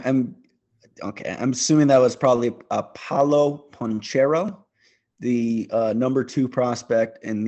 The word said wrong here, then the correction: Banchero: B-A-N-C-H? – Yeah. – Paolo Banchero.